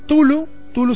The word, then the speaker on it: Cthulhu: Tulu se escribe C-T-H-U-L-H-U, ¿sí?